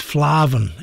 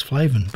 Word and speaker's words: Flavin: Now, it's pronounced or spelt Flavin, F-L-A-V-I-N, Flavin. But our good friend Jimmy McGee from Bow Divers has rang in and says, no, that's not how you pronounce it. You pronounce it, you put extra words and letters, or extra letters in there, which doesn't make much sense, but it is Irish. So it's Flavin, F-L-A-R-V-I-N, Flavin, he says.